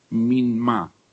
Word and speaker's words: mimma: Hay una palabra que en hebreo se pronuncia minma, M-I-M-M-A, minma. Creo que se acentúa en la A final, minma, quiere decir, tiene un valor doble, doble significado.